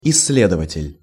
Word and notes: issledovatel': Russian

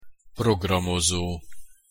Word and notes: programozo: Hungarian

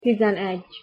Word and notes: tizenegy: Hungarian

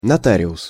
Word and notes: notarius: Russian

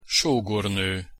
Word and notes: sogorno: Hungarian

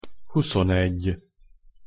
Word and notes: huszonegy: Hungarian

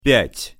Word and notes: pjat-: Russian

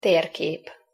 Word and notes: terkep: Hungarian

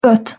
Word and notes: ot: Hungarian

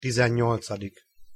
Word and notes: tizennyolcadik: Hungarian

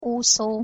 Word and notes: uszo: Hungarian

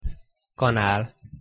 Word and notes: kanal: Hungarian

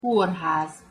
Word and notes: korhaz: Hungarian

